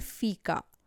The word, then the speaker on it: fika: While dealing with an emotional or mental breakdown or burnout, you can think about the Swedish noun which is called fika.